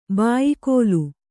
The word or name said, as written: ಭಯಾಕುಲೆ